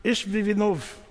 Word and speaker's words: Ishbi-Benob: Well, if you're a Hebrew person, you pronounce it Ishbi-Benob.